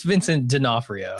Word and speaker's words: Vincent D'Onofrio: And he's played by Vincent D'Onofrio, who you'll recognize from Men in Black as the sugar water. What is it? – It's Vincent D'Onofrio.